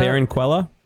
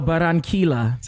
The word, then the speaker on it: Barranquilla: Barranquilla? – Okay. No, Barranquilla.